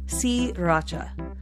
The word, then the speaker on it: Sriracha: Spelled S R I R A C H A, but the first R is silent, so it's pronounced Sriracha but in the United States, you will hear it more commonly referred to as sriracha. – You omit the R. You just say sriracha.